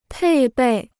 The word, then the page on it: 配备: pèi bèi